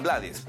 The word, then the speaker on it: Blades: él acepta que le digan Blades, pero su apellido realmente se debe pronunciar como Blades.